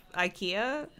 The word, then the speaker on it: Aich: Yeah, it's just called Ike. – Ike. – Scottish IKEA. Ike. – That's funny. – Oh, man. – But it's spelt like A-I-C-H.